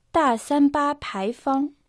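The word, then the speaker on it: та сан ба пай фан (大三巴牌坊): «Та» произносится в четвертом тоне. В переводе на русский это «большой». «Сан ба» – это не очень точная транскрипция имени Святой Павел. «Пай фан» произносится отдельно во втором и первом тоне.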